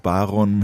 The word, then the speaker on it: Baron: Ja, der Baron mit der Betonung auf dem A und nicht hinten, das macht vielleicht den Unterschied.